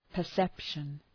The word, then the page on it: perception: pər’sepʃən